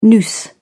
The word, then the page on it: noose: British English